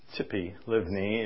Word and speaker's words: Tzipi Livni: Zippy Livni, is that how you say it? – It's Tzipi Livni is not actually the one who will be the prime minister.